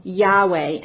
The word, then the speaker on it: Yahweh: And we pronounce the word Yahweh, and we have added vowels in it for our pronunciation of the word.